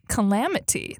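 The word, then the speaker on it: Kellamity: My name is Cassandra James Kellum, and I am on the internets as Calamity. That's K-E-L-L-A-M-I-T-Y.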